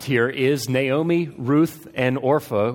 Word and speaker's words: Orpah: That's not supposed to be Oprah. It is Orpah. That's the way it's spelled.